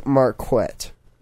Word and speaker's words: Marquette: It's pronounced Marquette.